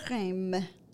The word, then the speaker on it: Reims: I believe, so here it is, La Ville de Reims. Did I say that correctly? – Yes.